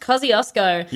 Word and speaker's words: Kosciuszko: And it is spelled, Tom, you spelt it wrong, but that is fine because it is pronounced Kosciuszko. – Yeah. – And it is spelled K-O-S-C-I-U-S-Z-K-O.